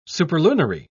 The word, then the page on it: superlunary: British English